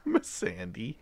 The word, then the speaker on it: Missandei: I thought it was Missandei at first, but watching the... – Missandei? – Like, after the episodes, if you actually watch on HBO, they have the creators talk about it, and they pronounce it that way.